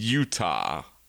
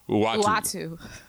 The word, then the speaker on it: Uatu: the Intersectional Hulk, the Cylon Don, Yoga Flame War, Resting Brand Frace, and Utah. No, not Utah. Uatu.